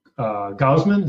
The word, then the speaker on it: Gausman: And, you know, you've added Ubaldo Jimenez, you've got Gonzalez, you've got, you know, young pitcher in Gausman. Is that how you pronounce it? – Yep. Yeah.